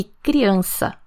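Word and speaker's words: crianza: criança. De novo, escreve com Z e pronuncia com S.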